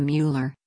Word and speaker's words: Mueller: My mother's maiden name was Stein and the Mueller is the German pronunciation, so."